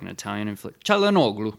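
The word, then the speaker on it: Çalhanoğlu: Kalinoglu. – Chalhanoglu. – You're right. C is J in Turkish.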